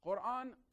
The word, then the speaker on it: Quran: Is it Quran or is it Quran? Quran, Qa, we don't have the letter in English. So the closest we have in the alphabet is Q. So it's the same book. I'm just pronouncing it the Arabic way.